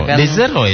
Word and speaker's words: The Zero: The Zero ya, bukan diinggriskan gitu ya. – The Zero. Sebenarnya itu pelafalan dari The Massive, Pak. Karena dulu itu kan kita lihat The Massive itu dari D terus Massive. – Oh baik baik. Tapi tetap milihnya D. TH gitu ya.